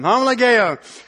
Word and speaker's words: homologeo: But confess, if you look up in the Greek, the Greek word there is homologeo. I think I said that right. I think that's the first time I've said that right. Thank you, Lord. Homologeo.